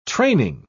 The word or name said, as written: training